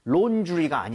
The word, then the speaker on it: laundry: 이것을 바로 laundry라고 하는데 A, U, A, W는 발음할 때 O예요? 어예요? 어에 더 가까워요. 완전 어는 아니지만 이 모양을 O보다는 벌려야 됩니다. 그래서 laundry, laundry가 아니에요.